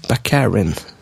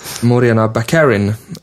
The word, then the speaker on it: Morena Baccarin: I det här fallet då, speaking of flickvän, så spelas flickvännen av Morena Baccarin. Baccarin. – Uttalet är on point. – Morena Baccarin. Morena Baccarin. Säger det du? – Morena Baccarin, om man ska ha ett amerikanskt uttal.